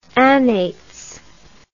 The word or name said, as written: annates